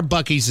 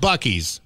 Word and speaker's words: Buc-ee's: Except it's spelled B-U-K-I-I-S. – Ooh, blaspheme. Someone's trying to imitate them. – See, our Bucky's is B-U-C-E-E-S. Mm-hmm. So down in Mexico, they have a mini Super Bucky's. Looks a lot like Bucky's.